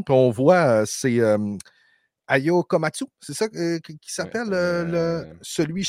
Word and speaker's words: Komatsu: Peut-être qu'on pourra me corriger sur la prononciation. – Je pense que c'est un Komatsu, si je ne me trompe pas. – Oui, effectivement, comme les pelles mécaniques et le commanditaire de William.